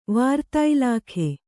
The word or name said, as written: ವಾರ್ತಾಇಲಾಖೆ